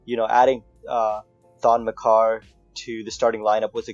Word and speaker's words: Maker: Makar. I think that's how you pronounce his real name. – Really? – Yeah. – Oh, it's not Thon Maker? – Nope.